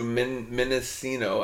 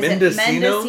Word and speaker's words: Mendocino: Okay, so this weekend we were heading to Mendocino. I always say that wrong. Mendocino. – Yeah, Mendocino, California. Which is the northern California coast.